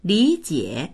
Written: li3jie3

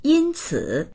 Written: yin1ci3

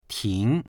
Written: ting2

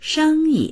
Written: sheng1yi

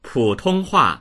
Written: pu3tong1hua4

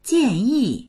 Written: jian4yi4

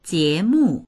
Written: jie2mu4

